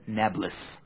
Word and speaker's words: Nablus: Arabs can't pronounce P, so today they call it Nablus.